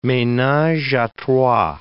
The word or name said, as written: menage a trois